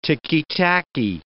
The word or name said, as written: ticky-tacky